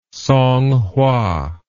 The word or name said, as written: Songhua